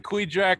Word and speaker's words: Kvichak: And that's the Quijack River. So it's spelled K-V-I-C-H-A-K.